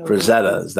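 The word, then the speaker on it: Frazetta: Ellie is, as everyone knows, you know, frizzettas, that's how you say it.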